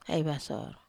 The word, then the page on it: ai vasor: Wamesa